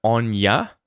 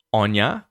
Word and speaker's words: on ya: And remember that in Australian pronunciation, if on ya is at the end of a phrase or there's no word that kind of comes after the ya, we'll pronounce it with that ah vowel sound.